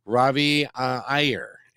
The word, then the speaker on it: Iyer: Ravi Iyer.